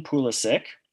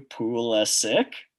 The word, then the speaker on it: Pulisic: It is Christian Pulisic. I even on the back, I kind of wrote it phonetically to help you. Pulisic. All right. Pulisic. I'm going to give Chris 5 points. Somehow, Chris, it's the first time he's pronounced it right all season.